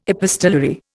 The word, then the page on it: epistolary: Indian